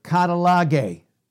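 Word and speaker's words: katallage: So we see that with reconciliation here in 2 Corinthians 5:18, which is this Greek word katalage. Katalage. K-A-T-A. We see that. A-L-L-A-G-E, long E. Katalage.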